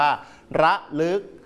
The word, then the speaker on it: ระลึก: คำว่าตะลึงกับคำว่าระลึกนักเรียนต้องออกเสียงอะให้ถูกต้องชัดเจนก็คืออ่านแบบอะเต็มเสียงต่อมาคำที่ 3 ครับ